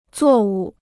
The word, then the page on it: 作物: zuò wù